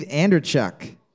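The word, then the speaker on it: Andreychuk: Is it A, I'm going to say this name wrong, Dave Anderchuk? – You nailed B, it.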